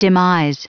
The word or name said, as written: demise